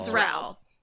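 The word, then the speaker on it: Xrell: It's X-R-E-L-L. – Yeah, it's one syllable. – Zrel.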